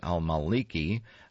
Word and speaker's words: al-Maliki: Sunnis have accused Shiite Prime Minister Nuri al-Maliki of targeting their community with arrests and spurious terrorism charges. Al-Maliki, I should have said. I mispronounced that. I'm sorry.